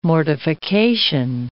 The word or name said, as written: mortification